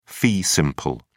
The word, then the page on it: fee simple: British English